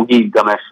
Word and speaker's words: Gilgamesh: Infatti noi diciamo Gilgamesh, no? Anziché Gilgamesh. – Sì. – Molti oggi lo pronunciano Gilgamesh, in realtà la pronuncia indoeuropea, insomma, era soprattutto, e soprattutto in Tira, non aveva la G morbida.